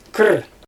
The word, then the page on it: kir: Xyzyl